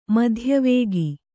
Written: ಮಧ್ಯವೇಗಿ

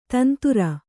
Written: ತಂತುರ

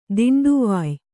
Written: ದಿಂಡುವಾಯ್

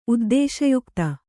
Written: ಉದ್ದೇಶಯುಕ್ತ

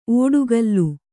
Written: ಒಡಕಲು

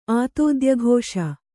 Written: ಆತೋದ್ಯಘೋಷ

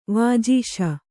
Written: ವಾಜೀಶ

